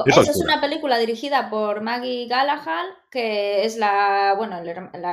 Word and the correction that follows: Gyllenhaal: hermana de Jay Gallagher, que lo he pronunciado mal, ¿no, no, no, no hay tanta diferencia con Maggie Gyllenhaal y Jake Gyllenhaal.